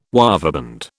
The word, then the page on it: waveband: British English